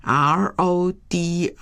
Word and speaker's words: Rodrigo: 那么我们先从吉他协奏曲开始，这首吉他协奏曲是这个西班牙的作曲家罗德利戈，罗德利戈这位作曲家，这个他的原文的名字是 RODRIGO， RODRIGO 用西班牙文来念的， Rodrigo。